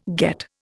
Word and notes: get: Indian

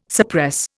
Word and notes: suppress: Indian

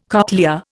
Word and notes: cochlea: Indian

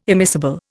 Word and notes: immiscible: Indian

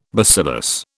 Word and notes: bacillus: British English

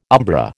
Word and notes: umbra: American English

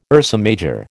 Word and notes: Ursa Major: American English

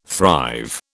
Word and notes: thrive: British English

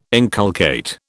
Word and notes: inculcate: British English

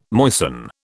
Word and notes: moisten: British English

